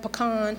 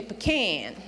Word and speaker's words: pecan: Did I say that right, pecan? Yeah. See, because where I come from, sometimes you say pecan, but it's pecan around here, right?